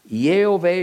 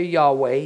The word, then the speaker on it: Yahweh: Yehovah or Yahweh, depending on which Hebrew scholar you believe is correct, is this name's pronunciation, is the completely unique word that God says is his personal name.